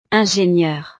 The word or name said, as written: ingenieur